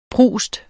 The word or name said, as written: prust